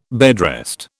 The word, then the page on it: bedrest: British English